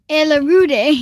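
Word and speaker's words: Ailuridae: Red pandas are the only living member of the Aelurudae. How would you say that? – Aelurudae, yeah.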